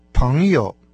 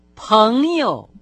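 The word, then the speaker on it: паньё (朋友): «Друг» по-китайски «паньё». Начальный слог с конечным носовым согласным произносится со вторым тоном, а следующий за ним с легким. – Пэньё.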